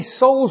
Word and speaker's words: Soal: So my surname is Soul, as in Daryl's soul, and it rhymes with your soul. But it's a good way to remember that as well, as you spell it S-O-A-L like coal.